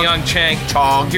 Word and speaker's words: Pyeongchang: Pyeongchang. – Here's a hint. It's not Chang.